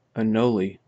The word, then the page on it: anole: American English